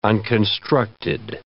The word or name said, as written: unconstructed